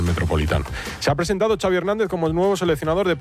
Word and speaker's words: Xavi Hernández: What is the correct pronunciation of your first and last name? – Xavi Hernández. – Xavi.